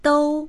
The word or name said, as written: dou1